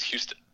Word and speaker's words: Houston: hear someone say Houston Street, it's Houston.